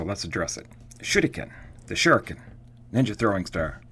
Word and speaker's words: shuriken: So it's pronounced shuriken, and the ri part in the middle is kind of like a rolling R, like you might find in French or Spanish.